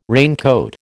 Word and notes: raincoat: American English

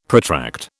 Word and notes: protract: British English

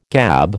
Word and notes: gab: American English

